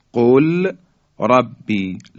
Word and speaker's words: قل ربی: خب، یا در حرف لام، لام ساکن در حرف را، دقت کنید، قل ربی، قل ربی، لام ساکن در حرف را ادغام میشه، مثل قربی، قربی. دیگه لام رو متلفظ نمی کنیم بلکه حرف را مشدد بیان میشه.